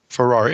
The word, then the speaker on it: Ferrer: I mean, is that and how you're saying Ferrari it right. is spelled? – Ferrar. It's spelled like Ferrer, right. You know, F-E-R-R-E-R. But I think it's like Ferrar. Ferrari without the extra.